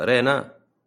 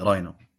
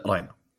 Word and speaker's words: راينا: أو هذه وش اسمها، ري ولا رينا؟ – إيه راينا. – راينا ولا رينا؟ – لا راينا، تنطق راينا.